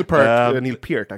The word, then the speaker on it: Peart: And maybe Neil Peart, actually, that's how you say it.